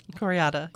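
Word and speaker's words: Kore-eda: Koreeda, yeah.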